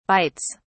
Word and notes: bites: British English

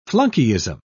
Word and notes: flunkyism: British English

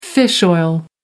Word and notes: fish oil: American English

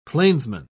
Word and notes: plainsman: British English

